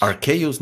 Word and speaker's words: Arceus: Arceus. No, es Arceus la pronunciación. Así la dicen en el tráiler.